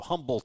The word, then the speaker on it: Humble: I wonder if you pronounce the H in Humble, Texas. You know, Yes, you do. Some people it's humble. – No, I'm more of a humble guy. No, it's humble.